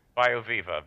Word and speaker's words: BioViva: And then you also have the bio, I'm not sure I'm going to pronounce it right, BioViva? – BioViva, V-I-V-A.